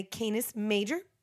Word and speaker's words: Canis Major: It's the dog star, the brightest one in the Canis Major. Did I say that right? – Yeah.